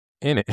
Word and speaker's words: innit: But the way they say it is just I-N-N-I-T. In it. – So yeah, but it sounds like you're just saying in it. And that's like, in what? – No, but that's how you say it.